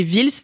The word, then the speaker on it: Vhils: Et mon préféré, c'était Vils, donc ça s'écrit V-H-I-L-S, et c'est un mec, mais alors, un bordel.